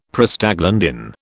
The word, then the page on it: Prostaglandin: British English